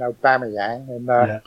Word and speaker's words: Aubameyang: I had Monreal and Aubameyang.